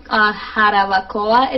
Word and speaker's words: Jarabacoa: So this was Jarabacoa, it's pronounced, but it's in the central mountain valleys of the Dominican Republic.